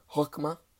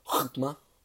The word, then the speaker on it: chokmah: In Hebrew, it's pronounced as chokmah, or you gotta kind of reach into the back of your throat to pronounce it, so it's chokmah.